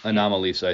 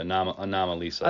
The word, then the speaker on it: Anomalisa: So so you're challenging me, and I gotta find the time here to watch Animal Lisa. Is that how you say it? – Anomalisa, I think it's like anomaly and then the name okay. Lisa. Anomalisa.